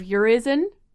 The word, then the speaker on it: Urizen: And I remember particularly when Northrop Frye was speaking about Blake's book of Urizen. – Yes. – Is that how you say it? – Yes.